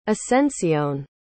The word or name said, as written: ascencion